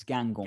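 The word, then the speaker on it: Guingamp: I am, yeah. Gangon. It's Gangon. – Gangon. – There we go. – Thanks. – It's all right. Yeah, 3-0, comfortable win. You'd expect Paris Saint-Germain to beat Gangon, let's be honest.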